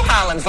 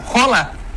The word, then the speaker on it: Haaland: Porque não é Erling Haaland que fala o nome dele. – Como é que fala? – Rola. – Aí, tá, é pior. – Erling Rola. – É Rola? Ele mesmo que fala, quando ele se apresenta, Erling Rola. Ele fala isso, não sou eu que tô inventando, não.